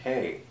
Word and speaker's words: He: This world was created through the sound of He, which is pronounced as a mere exhalation of breath. requiring little movement from the lip or tongue.